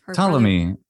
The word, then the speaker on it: Ptolemy: Ptolemy. Is usually how we say his name in English. – Okay. And it's spelled with it starts with a P. – Good old silent initial P in English.